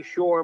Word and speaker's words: Shor: It's Larry Shor. My last name is spelled S-H-O-R, no E.